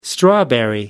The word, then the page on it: strawberry: American English